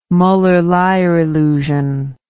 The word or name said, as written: muller lyer illusion